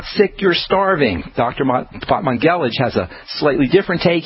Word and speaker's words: Batmanghelidj: by the Iranian doctor Feridun Batmanghelidj. That's spelled, his last name anyway, spelled B-A-T-M-A-N-G-H-E-L-I-D-J.